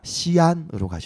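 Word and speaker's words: 西安: 시안으로 가셔야 돼요. – 시안. – 시안은 이제 중국 발음이고 한국 발음 서안.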